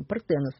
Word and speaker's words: Партенос: Но Дева – это был перевод уже современной историографии, и вот последние годы исследователи предлагают ее называть так, как она писалась в древнегреческих источниках – Партенос. На древнегреческий манер, в древнегреческом произношении – Партенос.